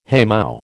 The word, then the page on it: haymow: American English